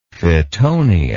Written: fittonia

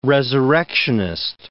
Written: resurrectionist

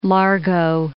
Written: Key Largo

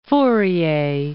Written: Fourier

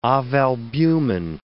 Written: ovalbumin

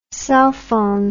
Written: sulfon

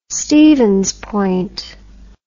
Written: Stevens Point

